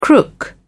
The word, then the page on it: crook: British English